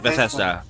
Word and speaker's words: Bethesda: I'm saying this wrong again. Bethesda? – Yeah, right? That's correct. Bethesda.